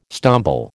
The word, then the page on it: stumble: American English